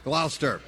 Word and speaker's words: Gloucester: Gloucester.